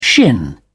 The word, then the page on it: shin: British English